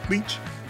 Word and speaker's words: Leitch: Hey, Will, is that L E E C H? Leach? – No, but it is pronounced just like the sucking parasite.